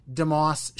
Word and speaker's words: DeMoss: Just type in Tim DeMoss Show.